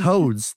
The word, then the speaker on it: Hodes: H-O-D-E-S? No. – Yeah. This is it. That's Hodes.